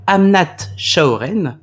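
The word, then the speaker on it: Amnat Charoen: Amnat Chaoren.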